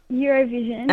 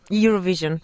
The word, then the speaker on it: Eurovision: Bene, ti fa ridere la pronuncia. Tu come lo dici? – Eurovision. – Ecco. Questi nativi, questi che parlano inglese da quando sono piccoli. Eurovision. Ok. Senti, grazie per averci chiamato.